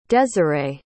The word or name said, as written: desirae